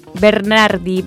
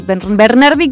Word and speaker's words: Bernardi: Mi Instagram, bueno, yo me llamo Natalie, se escribe Natalie Bernardi, Bernardi con B larga.